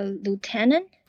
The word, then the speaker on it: lieutenant: How do we pronounce that LT? – a lieutenant. – Ah, lieutenant. There we go. Brilliant. LT, lieutenant. So most people who don't watch military movies, they don't know.